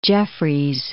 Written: Jeffreys

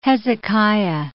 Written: Hezekiah

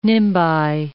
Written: nimbi

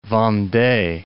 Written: Vendee

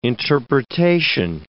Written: interpretation

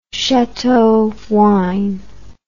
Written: chateau wine

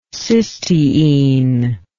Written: cysteine

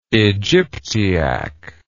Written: Egyptiac

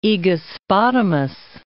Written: Aegospotamos

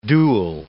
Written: duel

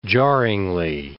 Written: jarringly

